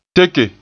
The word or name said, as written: teke